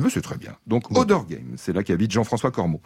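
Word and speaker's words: Auderghem: C'est Odergame ? – Odergame. – Odergame, oui.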